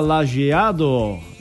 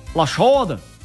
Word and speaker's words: Lajeado: Lajoda, é, no português bem pronunciado, se fala Lajeado, e agora no alemão, Lajoda, é.